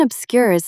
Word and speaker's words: obscure: Dense academic jargon often obscures simple truths. Stress the second syllable. Obscure. Say it aloud.